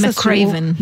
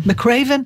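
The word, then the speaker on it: McRaven: אה, אמריקאי, ששמו וויליאם מקריבן או מקריון, אני לא בדיוק איך לבטא את זה, והוא באמת איזה מין דמות צבאית נורא נערצת בארצות הברית, והזמינו אותו לשאת אה, הרצאה באוניברסיטת טקסס. מקרייבן. – מקרייבן,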